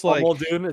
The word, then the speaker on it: Muldoon: Muldoon, he's Muldoon, is how you say it? – Oh, there's two oohs. There's two O's.